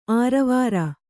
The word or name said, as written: ಆರವಾರ